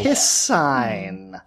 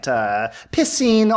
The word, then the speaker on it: piscine: Piss sign. – Oh. – Karen. – Fish. – Yes. – Yeah. Pisces. – Like Pisces. Yeah. Right, right. Piscine also is an acceptable pronunciation, but I want to keep them all predictable here.